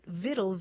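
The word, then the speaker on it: victual: And then Latin victualis passed into French, but along the way it lost that hard C sound, so it sounded more like vitaille. And that word for nourishment without the hard C found its way into English. And eventually, people spelled it Vittle, V-I-T-T-L-E, because that's sort of what it sounded like.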